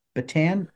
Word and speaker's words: Batten: And we pronounce your name Batten. Did I pronounce that right? – Batten, just like batten down the hatches.